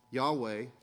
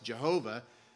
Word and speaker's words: Yahweh: Yahweh in the Hebrew has no vowels, and so your neighbors or your potential friends who want to convince you that it absolutely must be pronounced Jehovah are either unaware or dishonest about the fact that there is no letter J in the Hebrew language.